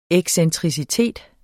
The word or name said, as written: excentricitet